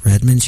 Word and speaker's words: Redmond: I'm like, Rich Redmond. She goes, how do you spell that? R-E-D.